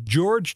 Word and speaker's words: George: Dus dat eh, bevestigde mijn vermoeden dat die naam inderdaad eh, zo moet worden uitgesproken. Katie George dus.